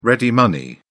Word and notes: ready money: British English

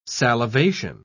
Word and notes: salivation: British English

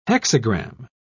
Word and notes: hexagram: British English

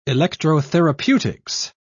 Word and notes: electrotherapeutics: British English

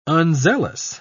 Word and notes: unzealous: British English

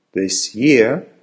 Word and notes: this year: American English

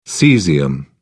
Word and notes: cesium: British English

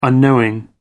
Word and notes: unknowing: American English

